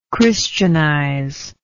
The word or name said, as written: Christianizer